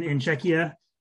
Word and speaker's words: Czechia: He took his he owns one of the pro teams in Czechia. Is that how you say it right? Czechia now? – Yeah, I believe so.